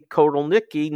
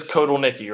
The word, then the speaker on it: Kotelnicki: Yeah, well, so it is Kotelnicki, right? That's the pronunciation we're running with? – That's what we're going with.